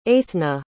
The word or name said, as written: Eithne